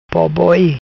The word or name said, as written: Boboi